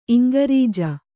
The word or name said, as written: ಇಂಗ್ರೆಜ